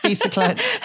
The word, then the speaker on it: Bicyclette: Bicyclette.